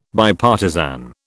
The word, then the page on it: Bipartisan: British English